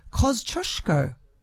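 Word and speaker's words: Kościuszko: He says, as a person of Polish heritage, it was great to hear Tristan from the Bomb give Kosciuszko, that's how I'm pronouncing it, its correct pronunciation, Kosciuszko.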